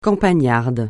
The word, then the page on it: campagnard: francais